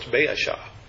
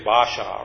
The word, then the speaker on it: Baasha: And so you pronounce all the letters. So it's Baasha. And whereas we would say Basha or something like that, if we just look at it and say, well, that's what sheep say.